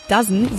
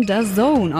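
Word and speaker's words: DAZN: dass die Firma mit den vier Buchstaben nicht Dozen, sondern The Zone ausgesprochen wird.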